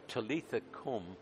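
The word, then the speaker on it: Talitha cum: Taking the child's hand, he said tenderly to her, "Talitha, come." Is that how you would pronounce that, sir? Cum. You'd get the cum, would you? Yeah. So, "Talitha, cum," which translated from Aramaic means, "Little girl, I say to you, get up."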